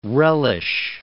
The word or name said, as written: relish